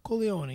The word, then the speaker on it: Corleone: Is that how they pronounce it in Italian? – Corleone, yes. The city of Corleone.